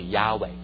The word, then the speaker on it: Yahweh: and then if you put the right sound in there, the proper pronunciation should be Yahweh.